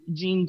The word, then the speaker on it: dzień: I'm like, okay, so they have, the way it's spelled is D-Z-I-E-N. So D-Z makes like a J sound. So dzień dobry.